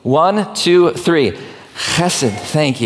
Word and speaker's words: chesed: And really, it's a word that sounds like you're clearing your throat. It's pronounced chesed or chesed like that.